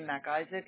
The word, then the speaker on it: MacIsaac: The initial S as in Sue, last name MacIsaac.